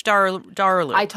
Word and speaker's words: Kılıçdaroğlu: So Kılıçdaroğlu, who has been a long time— I think it's Kılıçdaroğlu.